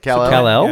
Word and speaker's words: Kal-El: Kal-El? Is that how you say it? – Kal-El? – Yeah.